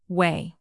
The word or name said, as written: whey